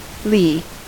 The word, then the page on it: lee: American English